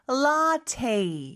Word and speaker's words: latte: 자, 일단 latte는 라떼이겠네요. 그렇죠? 발음이 조금 다르네요. 그렇죠? 영어로는 라테 이렇게